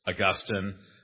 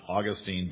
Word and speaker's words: Augustine: When we're referring to the famous Augustine, the conventional pronunciation is not Augustine, but Augustine. So now you know.